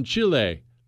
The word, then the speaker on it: Chile: You remember we did that episode on Chile last week.